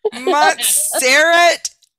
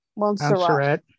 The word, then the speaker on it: Montserrat: Montserrat Caballera. – Oh my gosh. You can't read it. We don't need it. – Montserrat, M-O-N-T-S-E-R-R-A-T. – Montserrat.